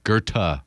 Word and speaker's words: Goethe: V-O-N-G-O-E-T-H. That's Goethe? – Goethe. – Well, you don't pronounce the T-H.